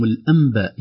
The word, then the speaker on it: الأنباء: أحيانا كنا نضرب الأمثال بمقدم يقول إليكم الأنباء والتفصيل، وهو مقدم يعني وليس مجود يعني ولا يرسلها ولا شيء، يقول إليكم الأنباء ينطقها ميم ومكتوبة نون،